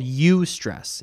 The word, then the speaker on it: eustress: It's called eustress. It's spelled with E-U and then stress.